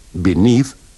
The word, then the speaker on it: beneath: In these dialogues, remember to pronounce the first E of below, beneath, beside, and between with the sound of the I in sit.